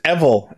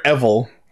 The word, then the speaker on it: Evil: Yeah, so I actually listened to a clip of the new Kamen Rider's Henshin from Ganba Rising, and apparently it is pronounced Evil, as in the word devil without the D, which makes which makes sense. So it is, it is in fact Kamen Rider Evil.